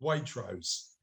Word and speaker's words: Waitrose: Sorry, I thought Waitrose was a posh shop. – The name of the shop is WARTROSE. Waitrose. That's how you say it.